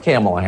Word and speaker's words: Kamala: Her name is pronounced comma, like the punctuation mark. Look, Kamala.